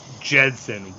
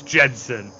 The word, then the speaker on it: Gedson: It's Gedson. Why can't you guys say it? It's just a soft G. – American. – George Gedson. – I live on this continent, so I don't have to learn other languages. – Like, you know the Jetsons? It's Gedson. It's that.